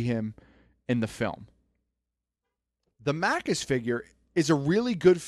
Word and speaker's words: Maccus: would be Davy Jones and Maccus. – Is it Maccus? – I think so. It's M-A-C-C-U-S, so I'm assuming the C's are hard C's.